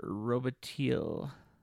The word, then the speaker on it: Robitaille: The Candyman and Daniel Robitaille. Am I pronouncing that right? – Close enough.